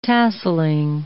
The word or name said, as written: tasselling